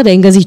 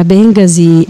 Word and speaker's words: Bengasi: E io continuo a pronunciarla alla torinese, chiedo scusa agli ascoltatori, magari quelli che ci tengono la pronuncia più corretta, però io dico sempre se vuoi andare a Bengasi prendi l'aereo, se vuoi andare a Bengasi prendi la metro.